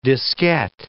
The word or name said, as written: diskette